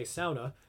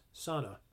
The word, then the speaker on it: sauna: When I say sauna, people from the Upper Peninsula or Finland, where they originate from, will know that I'm saying that correctly. Quick pin. Everybody else will go, it's pronounced sauna. No, it's not. It's sauna. Do your research.